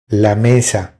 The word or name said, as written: La mesa